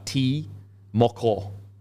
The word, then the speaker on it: Timoko: So that's like, so for example, Timoko, which is T-I-M-O-K-O, is T-Moko.